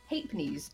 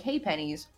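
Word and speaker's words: ha'pennies: It's apparently pronounced Hapenies. It's spelled like Haypennies, but it's pronounced like Hapenies.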